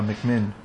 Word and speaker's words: McMinn: Terry McMinn. I almost mispronounced her name there. – Uh huh, McMinn.